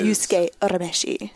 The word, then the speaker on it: Yusuke Urameshi: Tsubasa Sakiyama as Yusuke Urameshi.